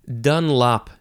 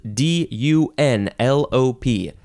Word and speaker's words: Dunlop: dunlop is next and you can emphasize either syllable. It has a capital D and it is spelled D U N L O P, Dunlop or Dunlop.